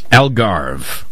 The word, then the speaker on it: Algarve: Algarve. – Algarve coastline, and so much more.